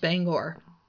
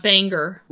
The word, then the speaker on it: Bangor: And it's pronounced Bangor, not Banger.